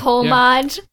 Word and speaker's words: homage: You say homage. – Yeah, he says homage all the time. – Yeah, I say homage. – Homage. H is silent.